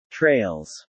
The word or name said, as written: trails